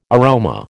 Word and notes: aroma: American English

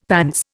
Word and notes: pants: Indian

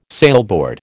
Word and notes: sailboard: American English

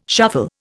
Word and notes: shuffle: Indian

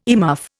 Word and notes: earmuff: Indian

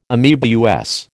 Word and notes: ameba: American English

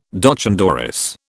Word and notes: doch an dorris: British English

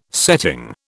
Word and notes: setting: British English

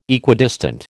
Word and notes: Equidistant: American English

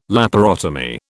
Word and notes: laparotomy: British English

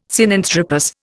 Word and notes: Sinanthropus: Indian

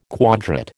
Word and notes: quadrat: American English

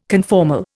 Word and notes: conformal: Indian

